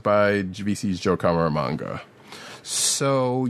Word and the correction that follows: Caramagna: How can you butcher the name of our favorite paisan? Caramagna.